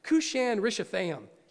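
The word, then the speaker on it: Cushan-rishathaim: Cushan Rishathaim. Yeah. In my notes, it says K-E-W, not in here, but in my office, K-E-W Rishathaim. It's all spelled out that way. It's phonics 101. So Cushan Rishathaim, here's this guy.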